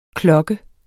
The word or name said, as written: KLOKKES